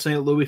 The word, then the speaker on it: St. Louis: It's St. Louis. Sometimes we call it St. Louis for short and for fun, but it's, yeah, St. Louis.